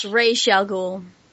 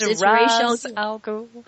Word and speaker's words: Ra's al Ghul: Is it Ra's al Ghul or Ra's al Ghul? – It's Ra's al Ghul. – Am I pronouncing this correctly? – Yeah, it's Ra's al Ghul. – Ra's al Ghul. But in the Nolan films, they mispronounce it, or they pronounce it differently. Yeah. It's Ra's in Nolan, and it's Ra's in animated series.